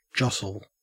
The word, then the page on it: jostle: Australian English